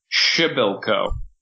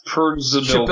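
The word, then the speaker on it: Przybyłko: I'll add that I think, I could be wrong, but I think it's pronounced Kasper Schibilko, not Perzibilko. – Schibilko sounds right.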